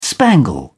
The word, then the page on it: spangle: British English